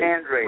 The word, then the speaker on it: Andrade: And it's Andre, right? That's Andre. – How you say that? Andre. – Andre. – Raid. – Yep. – Raid. But it's not spelled like Raid the spray.